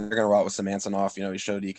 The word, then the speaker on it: Samsonov: They're going to roll out with Elias Samantsov. – Okay. Again, I butchered his name. – Okay, yeah, I know who you're talking about. Samsonov? – Is that yeah, Samsonov.